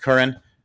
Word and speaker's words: Curhan: Jake Curran, or Curhan, I believe it's pronounced Curran, grew up in San Francisco.